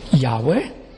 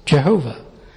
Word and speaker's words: Yahweh: Yahweh, sometimes wrongly pronounced Jehovah.